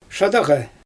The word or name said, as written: ʃadaɣej